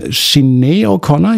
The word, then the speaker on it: Sinéad O'Connor: Queen of Denmark gibt es dann auch noch in der Version von Sinéad O'Connor, ich glaube, so spricht man sie aus.